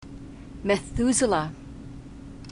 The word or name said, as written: methuselah